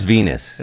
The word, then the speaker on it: Venus: Venice, I don't know. It's something like that. – It's Venus. – Is that what V-E-N-U-S, it is? Yeah. Rhymes with Venus.